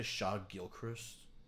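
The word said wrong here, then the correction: Shai Gilgeous-Alexander: What are they? Shaw Alexander Gilchrist? – No. Shaw Gilgis Alexander.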